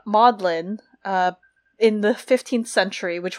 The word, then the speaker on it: Magdalen: Um, it actually, though, is not pronounced the way that we think it is. It is, in fact, pronounced kind of like Maudlin. Maybe you all know this if you're some of our uh, listeners in the UK. You probably know this, but it's pronounced as Maudlin because during the time of its founding, in like, what is it, 1458, Magdalene mm.